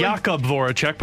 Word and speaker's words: Jakub Voracek: So, Jake Voracek, am I pronouncing his last name correctly? – Jakub Voracek, but close enough.